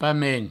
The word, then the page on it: pasmens: pamens